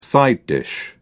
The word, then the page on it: side dish: British English